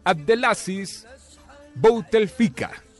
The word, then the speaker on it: Abdelaziz Bouteflika: El actual presidente es, escuchen este nombre, Abdelaziz Bouteflika. No sé si lo dije bien, Fabito. – Ah, muy bien.